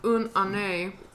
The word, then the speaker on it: une année: Okay, for French speakers, it's actually pronounced une année, which means 1 year.